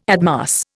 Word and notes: admass: Indian

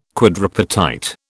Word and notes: quadripartite: British English

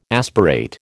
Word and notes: aspirate: American English

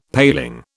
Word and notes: paling: British English

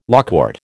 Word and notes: lawcourt: American English

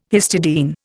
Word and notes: histidine: Indian

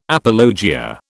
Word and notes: apologia: British English

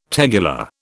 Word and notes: tegular: British English

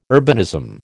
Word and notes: urbanism: American English

